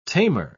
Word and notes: tamer: British English

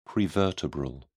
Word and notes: prevertebral: British English

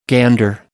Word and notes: gander: American English

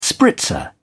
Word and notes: spritzer: British English

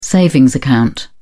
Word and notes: savings account: British English

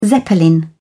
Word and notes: zeppelin: British English

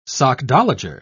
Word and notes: sockdologer: British English

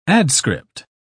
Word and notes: adscript: British English

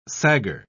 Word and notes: sagger: British English

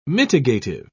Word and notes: mitigative: British English

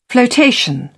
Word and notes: floatation: British English

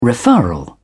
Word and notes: referral: British English

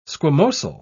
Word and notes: squamosal: British English

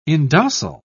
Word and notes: indocile: British English

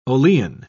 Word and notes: olein: British English

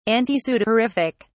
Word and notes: antisudorific: British English

